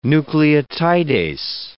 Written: nucleotidase

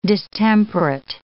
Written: distemperate

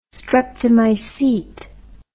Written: streptomycete

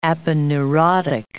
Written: aponeurotic

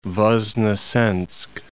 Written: Ivanovo Voznesensk

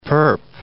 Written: perp

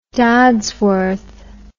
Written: Dodsworth

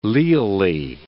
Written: leally

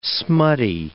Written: smutty

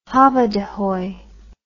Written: hobbadehoy

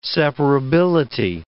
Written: separability

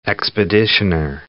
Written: expeditioner

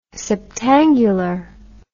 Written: septangular

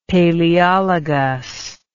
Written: palaeologus

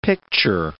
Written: picture

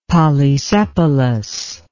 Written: polysepalous

